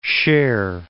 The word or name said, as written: Cher